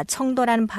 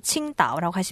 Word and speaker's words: 青岛: 청도라는 발음은 중국어 발음대로 칭다오라고 하시면 돼요.